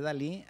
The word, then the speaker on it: DALL-E: aunque no se escribe igual, se escribe D-A-L-L-E, pero igual se pronuncia Dalí.